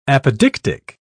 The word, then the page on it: apodictic: British English